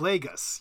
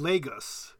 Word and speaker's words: Lagos: It's Lagos, yes. But I think you can say, in the Foreign Service, I always said Lagos, actually. Maybe I didn't pronounce it correctly either.